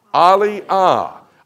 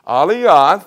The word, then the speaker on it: Aliyah: That is a Hebrew word. Say that word. Aliyah. Aliyah. The next page will tell you how to spell it. A-L-I-Y-A-H.